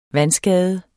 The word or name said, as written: vandskade